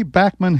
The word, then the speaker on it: Bachman: Let me just say that Randy Bachman himself tells us here that the proper pronunciation is indeed Bachman. – Canadian is Bachman. If I phone somebody in the States to say it's Randy Bachman, they go, who?